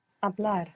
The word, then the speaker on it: hablar: To speak began with a silent H. I don't know if you remember this verb. Hablar.